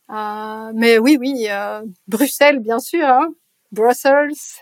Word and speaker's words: Bruxelles: parce qu'on a tendance à le prononcer Bruxelles, alors que c'est Bruxelles. – C'est Bruxelles.